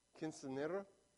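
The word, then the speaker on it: Quinceañera: Last Saturday, I went to Anaya's Quinceanera. Oh, I said it right. Went to Anaya's Quinceanera.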